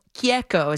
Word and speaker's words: Chieco: At Michael Chieco is how you say it in Italian.